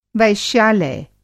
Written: वैश्यालय

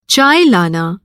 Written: लाना

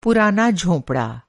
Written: झोंपड़ा

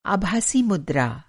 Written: आभासी मुद्रा

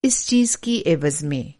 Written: एवज़

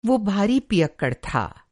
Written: पियक्कड़

